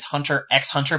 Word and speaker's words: Hunter x Hunter: It's Hunter x Hunter, but the X is not pronounced.